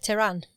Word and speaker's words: Tehran: Tehran.